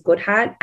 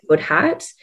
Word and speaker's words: Goodhardt: Sweetheart is spelt like my surname, which is Goodhardt. It's SWEETHARDT mm-hmm. because my surname is Goodhardt.